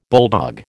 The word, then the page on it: bulldog: American English